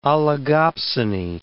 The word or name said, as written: oligopsony